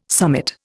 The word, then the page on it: summit: Indian